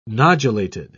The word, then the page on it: nodulated: British English